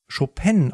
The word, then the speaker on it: Chopin: Er wollte auch immer übrigens polnisch Chopin ausgesprochen werden.